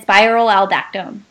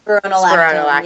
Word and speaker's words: spironolactone: And then spiral aldactone, I think is the other one. – Spironolactone.